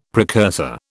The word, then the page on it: precursor: British English